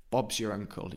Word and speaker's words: Bob's your uncle: So Bob's your, we don't really pronounce the R of your. Bob's your uncle, Bob's your uncle, Bob's your uncle. Not ankle, ankle is what you have by your foot, ankle.